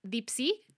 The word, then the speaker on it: Dipsea: Luego hay otra, una aplicación que se llama Deep Sea, se escribe Dipsea,